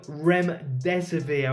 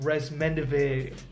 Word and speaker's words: Remdesivir: so far, because we don't know what's going to happen, nothing's official yet, but the drug Remdesivir, I was calling it Resmendivir in the last video,